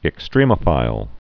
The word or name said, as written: extremophile